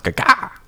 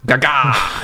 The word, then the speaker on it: Gaga: I find it's pronounced Gaga. – Gaga.